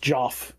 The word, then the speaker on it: Geoff: It's spelled the old way, though. It's like G-E-O-F-F. Like Joffrey, almost. Like Joffrey, but yeah. – But just Joff. It's like Jeff Castellucci.